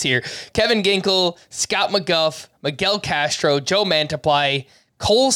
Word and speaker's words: McGough: Well, it's a fellow Scott. Scott McGuff. – Yeah! – M-C-G-O-U-G-H is how you spell that.